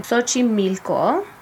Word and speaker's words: Xochimilco: Xochimilco, borough of Mexico City in 1921.